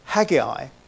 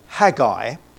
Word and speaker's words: Haggai: Now, this minor prophet and his book, how do we pronounce his name? I've always called it over the years Haggai. And I did wonder whether it should be Haggai. I think we're now calling Sinai, Sinai, but I did look into it, and it appears that either pronunciation is correct. I'm tending to call him now Haggai, but I apologize in advance for any inconsistency during this talk in that pronunciation. It really doesn't matter. Now, first of all, I'm going to give you the historical context of this book.